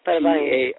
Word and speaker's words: parabéns: Yes, parabéns. – P-A-R-A-B-E-N. Yeah, parabéns.